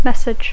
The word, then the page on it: message: English